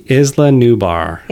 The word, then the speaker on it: Isla Nublar: It's the Isla Nublar.